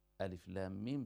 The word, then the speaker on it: الم: ألف لام ميم تنطق باسم كل حرف، الأول تقول ألف، ثاني لام، ثالث ميم، بينما ألم ترى تنطق بذوات الحروف كما هي.